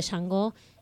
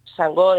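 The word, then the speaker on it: Xangó: Para los oyentes les recordamos, les decimos que Yango se escribe con X y con G. – Sí. – más allá de que se pronuncie Yangó, como para que puedan buscarlo. – Tal cual, tal cual. Yangó, dentro de lo que es el sincretismo africano, es el...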